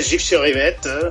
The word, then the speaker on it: Gif-sur-Yvette: Que nos está escuchando desde Francia, de la localidad de Gif-sur-Yvette. ¿Es así? ¿Lo dije bien? – Así es Gif-sur-Yvette.